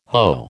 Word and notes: hoe: American English

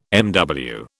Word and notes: MW: British English

